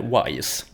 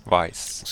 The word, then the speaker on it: Vice: Och då uttalade jag uppenbarligen eh, Vice City med Wise. Vice.